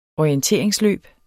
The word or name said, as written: orienteringsløb